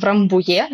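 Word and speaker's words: Рамбуйе: Или переговоры в Рамбуе, да, потому что это такой французский замок, поэтому ударение на последний слог.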